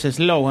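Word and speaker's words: Slough: En inglés, el nombre de la localidad y centro de oficina, Slow, se pronuncia fonéticamente muy parecido a slow, a lento.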